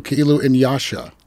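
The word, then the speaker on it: Kiilu Nyasha: Ki'ilu, Ki'ilu Inyasha. – Yes. – We're going to spell that for the viewer, listeners and viewers. K-I-I-L-U-N-Y-A.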